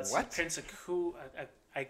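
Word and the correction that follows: Iaukea: Prince Iakea.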